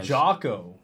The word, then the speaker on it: Jocko: Oh, Jocko. Like Jock with an O at the end. – No, Jock. I think Jock would be J-O-C-K-O.